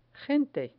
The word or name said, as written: gente